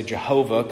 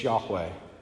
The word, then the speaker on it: Yahweh: Some may say Jehovah, coming out of the pronunciation amongst the Germans, but it's Yahweh.